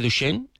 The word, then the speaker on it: Duchenne: lo digo bien, distrofia muscular de Duchenne, ¿es así? – Sí, correcto.